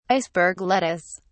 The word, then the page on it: iceberg lettuce: British English